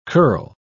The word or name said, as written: curl